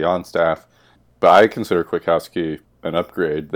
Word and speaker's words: Kwiatkowski: Pete Kwiatkowski, I believe is the correct pronunciation. Not going for my full-on true Polish there.